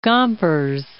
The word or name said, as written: Gompers